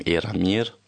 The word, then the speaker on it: Eramir: Also, ja, der Name heißt ausgesprochen, also wirklich auch ausgeschrieben, Eramir.